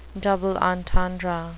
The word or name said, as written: double entendre